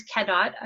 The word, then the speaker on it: Cadotte: And the last name is Cadotte.